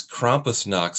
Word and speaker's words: Krampusnacht: What I love about the other things that you do is they're so odd and weird, because I came across the Milwaukee Krampusnacht. I think that's how you say it. – Krampusnacht, yes.